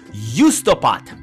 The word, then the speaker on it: Juustopäät: They're called Justopat. This is apparently how it's pronounced. I made the last time I played them, apparently I pronounced it correctly.